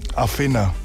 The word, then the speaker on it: Athena: Athena.